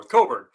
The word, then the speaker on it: Cockburn: I'm going to go with Curbelo and Kofi Cockburn. I always say it wrong. Coburn at Illinois.